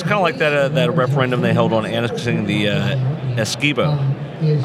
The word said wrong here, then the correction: Esequibo: Not Esquibo. Esequibo.